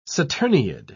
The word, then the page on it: saturniid: British English